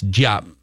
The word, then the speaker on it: Diop: This Bates Diop is that how you pronounce Bates? – Diop. – Bates Diop. Bates Diop. – Diop. – Yeah. I think we're going Diop now.